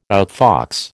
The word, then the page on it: outfox: American English